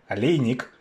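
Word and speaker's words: Oleinik: starting with I suppose Lewis versus Alejnik, or if you want to say his name properly, Alejnik.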